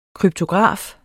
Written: kryptograf

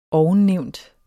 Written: ovennævnt

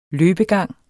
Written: løbegang

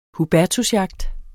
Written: hubertusjagt

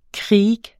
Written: kriek

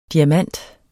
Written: DIAMANTEN

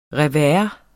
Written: revers

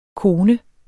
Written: kone

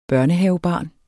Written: børnehavebarn

